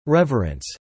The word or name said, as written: reverence